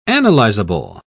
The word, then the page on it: analyzable: British English